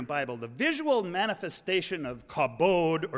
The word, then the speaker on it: kabod: But that word harkens back to a Hebrew term for glory, kaf bet dalit, usually pronounced kabod. Say it with me, kabod. That's glory in Hebrew.